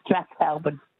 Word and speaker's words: Strathalbyn: Is that the pronunciation? – Yeah, well. – Or Strathalbyn. – Oh, yes. You say Strathalbyn. – Strathalbyn. Okay. – Yes, Strathalbyn. But if you say Strathalbyn, anyway, blah, blah, blah. – Both work.